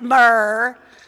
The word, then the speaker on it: myrrh: That's exactly how you say it. Myrrh.